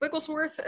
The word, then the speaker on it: Wigglesworth: Wigglesworth is spelled WIGGLESWORTH.